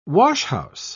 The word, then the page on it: washhouse: British English